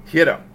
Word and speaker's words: gyro: Hiro. How do you say it? You're Greek. – Hiro.